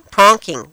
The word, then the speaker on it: pronking: Boinking? Is that right? Did I say it right? – Pronking? – Pronking. Yeah, there you go.